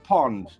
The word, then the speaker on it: pond: A pound in Dutch is pronounced pond.